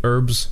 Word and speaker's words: herbs: You know is the H in herbs silent? American pronunciation of herbs is without the H.